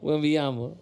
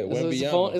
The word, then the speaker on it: Wembanyama: She's accusing Wembanyama's security. – Say it again. – Wembanyama? – Wembanyama. – Yeah, Wembanyama.